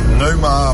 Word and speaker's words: Pneuma: Als je tijd hebt en een gaatje kan vinden in jouw drukke schema vandaag, zou jij dan Neuma kunnen draaien van Tool? Pneuma, ik geloof dat je Neuma moet uitspreken.